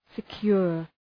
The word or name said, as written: secure